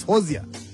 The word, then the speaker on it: Hozier: Is it Hozier? How do you pronounce it? Hozier. Hozier. I think so. Yeah. The single mayaka itatileyo eti Sherry Wine. Hozier. Yeah, it's Hozier.